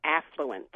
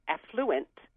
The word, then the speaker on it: affluent: But lately I've heard it pronounced affluent, mm-hmm. – and it reminds me of the word effluent.